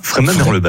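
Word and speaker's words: Freyming-Merlebach: J'ai bien dit Fréminges ou Fréminges ? – Fréminges, Merlebach. – Ah ben, j'ai bien prononcé.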